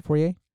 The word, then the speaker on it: foyer: Is that how you pronounce it? Foyer? – Foyer. – Okay. There we go. Foyer. And the foyer. And so, Foyer. – That does sound better.